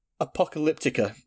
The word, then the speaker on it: Apocalyptica: I hate that I can't pronounce that word very well. – Apocalypto. – Apocalyptica. – Apocalyptica.